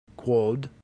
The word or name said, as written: quod